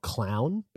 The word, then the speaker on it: Klovn: So there's this movie that I watched a number of years ago called Clown, but it's spelled K-L-O-V-N.